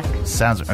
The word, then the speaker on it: Sazerac: Sazerac.